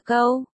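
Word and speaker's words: câu (够): Một, Rách nói đủ, câu tiếng Trung đọc là câu, nghe gần giống như gâu trong tiếng Anh.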